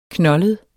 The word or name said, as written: knoldet